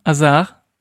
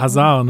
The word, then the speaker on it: Hazard: Azar. Eden Azar. Wird auch äh, in der Regel falsch ausgesprochen. – Was, was sagen die Leute? Hazard, ne? Hazard.